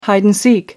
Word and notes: hide-and-seek: American English

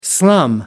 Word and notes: slum: American English

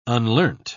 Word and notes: unlearnt: British English

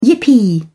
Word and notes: yippee: British English

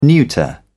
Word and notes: neuter: British English

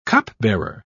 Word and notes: cupbearer: British English

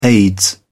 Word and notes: aids: British English

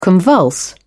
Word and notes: convulse: British English

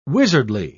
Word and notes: wizardly: British English

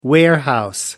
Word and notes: warehouse: American English

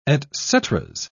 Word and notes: etceteras: British English

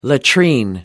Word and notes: latrine: American English

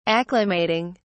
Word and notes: acclimating: British English

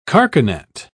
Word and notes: carcanet: British English

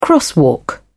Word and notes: crosswalk: British English

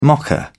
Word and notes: mocha: British English

